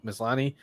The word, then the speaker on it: Maslany: I got to say the lead actress, Tatiana, how do you say her last name? Maslany.